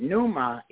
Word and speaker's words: pneuma: I'm pronouncing it pneuma. It's spelled P-N-E-U-M-A. P-N-E-U-M-A. But you pronounce it as pneuma, like okay.